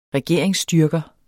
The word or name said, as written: regeringsstyrker